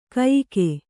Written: ಕಯಿಕೆ

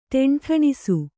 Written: ಢೆಂಢೆಣಿಸು

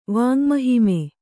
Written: ವಾಙ್ಮಹಿಮೆ

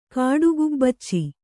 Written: ಕಾಡುಗುಬ್ಬಚ್ಚಿ

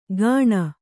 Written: ಗಾಣ

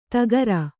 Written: ಧಕಾರ

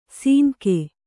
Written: ಸೀನ್ಕೆ

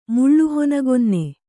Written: ಮುಳ್ಳುಹೊನಗೊನ್ನೆ

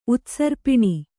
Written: ಉತ್ಸರ್ಪಣ

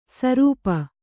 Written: ಶ್ರಾಪ

